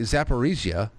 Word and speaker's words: Zaporizhia: because the Zaporizhia,